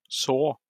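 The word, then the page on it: så: Norwegian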